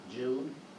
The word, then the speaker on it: Jude: Jude? – Mm-hmm.